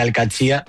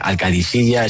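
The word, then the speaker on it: Al-Qadsiah: Bueno, se suele decir Al-Katsiya. – Al-Katsiya. – Pero también hay gente que dice Al-Kadisiya. Es que es difícil, ¿eh? Ah. No es fácil, pero Al-Katsiya es lo más fácil.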